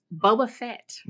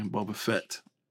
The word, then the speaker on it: Boba Fett: Boba Fett. – Okay, Boba Fett. – It's cool. – Not Boba Fett. Not Boba Fett.